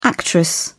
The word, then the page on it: actress: British English